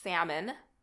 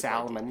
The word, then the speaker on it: salmon: I used to pronounce salmon with the L, but I think a lot of people salmon.